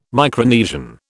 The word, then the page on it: Micronesian: British English